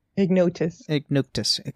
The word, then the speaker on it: Ignotus: And then we get to Ignosus as passing and unusual friend. So Ignotus.